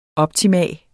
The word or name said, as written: OPTIMUMMETS